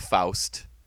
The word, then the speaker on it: Faust: In this minute, we see the conclusion of our little theatrical show of Faust. Did I say that right? – Shockingly, yes.